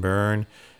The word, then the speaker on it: Byrne: He brought back some memories, you know, because there were certain artists, John Byrne. I think it's pronounced his name, B-Y-R-N.